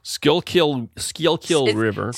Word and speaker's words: Schuylkill: And then there's another river you have to cross called the Schuylkill. And I will just... – Schuylkill? – It's spelled S-C-H-U-Y-L-K-I-L. – K-I-L. Schuylkill River.